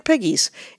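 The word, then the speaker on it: Pegues: And her grandfather insisted on pronouncing it Piggies. It's P-E-G-U-E-S. – Oh. – Which I would have gone with a French, Pegs, or something like that.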